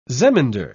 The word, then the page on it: zemindar: British English